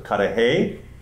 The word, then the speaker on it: Cudahy: First question I asked the judge, I said, how do you pronounce it, Cudahy or Cudahy? And he said, if I'm in Wisconsin, it's Cudahy. If I'm in Illinois, it's Cudahy.